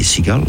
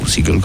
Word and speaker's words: Segall: Ty Segall, Segall, Segall, Segall comme la mouette. Mais ça s'écrit pas du tout pareil.